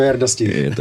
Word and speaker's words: Jarda Štich: Jak se to vyslovuje? Nemám žádný problém. Ale je to Jarda Štich.